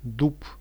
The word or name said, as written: dub